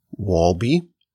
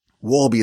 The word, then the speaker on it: Wolbe: My name is Yaakov Wolbe. Wolbe is spelled W-O-L-B-E.